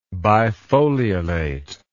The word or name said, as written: bifoliolate